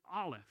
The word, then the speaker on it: Aleph: The first one is Aleph. It's kind of like olive, but you put an ah and a ph on the end. All right, so say it with me, Aleph.